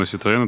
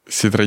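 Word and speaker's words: Citroën: машина Citroёn, это типа гражданская машина, правильно, во Франции? Ситро не Citroёn, Citroёn.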